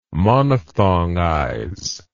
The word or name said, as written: monophthongize